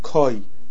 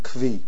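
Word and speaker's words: koi (כוי): So I saw from Chaim Kamievsky that the proper way to pronounce this word is koi, not kvi.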